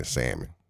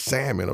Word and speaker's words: salmon: But I want you to go spread the gospel at church of not pronouncing the L in salmon. Okay, that's what I want you to do going forward. That's going to be your ministry, okay? the Don't Pronounce the L Ministry. For real, Grandma. I said, Grandma, it's salmon, okay?